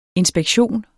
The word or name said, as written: inspektion